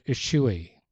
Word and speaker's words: ischui: It's the word ischui. Gesundheit. Spelled I-S-C-H-U-I.